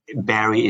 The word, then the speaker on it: Barrie: Or you type my name, Barrie is spelled B-A-R-R-I-E, last name is Tan.